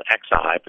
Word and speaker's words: Xi: Michelle is going to get pushed aside perhaps tomorrow, and President Xi, which is spelled X-I, but pronounced S-H-E-E, Xi,